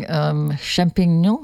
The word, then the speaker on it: Champignon: Champignon, am I pronouncing that correct? – Yes, the French word for mushrooms.